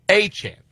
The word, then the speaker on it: Etienne: Travis Eitan. That is how it is pronounced. Eitan.